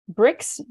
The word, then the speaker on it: Brix: And then my middle name is Brix, B-R-I-X, and then the A for Anderson.